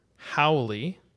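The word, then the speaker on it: haole: Howley. – Yep. – Okay. And I think I pronounced that correctly. – You did.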